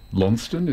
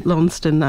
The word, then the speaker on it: Launceston: Just think of Launceston, but add another syllable in the middle. – Okay. – You got it, yeah. – Launceston, add another, or Lonnie.